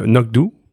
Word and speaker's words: anCnoc: Et qui développe un single malt, alors qui s'écrit A-N-C-N-O-C, mais qui se prononce Ça se prononce donc Euh, Nokdu, donc, qui est la colline noire en gaélique, et Anok, qui est adossé à la colline, tout simplement.